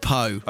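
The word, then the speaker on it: Pau: Pow. Although someone's been on saying it's pronounced Poe.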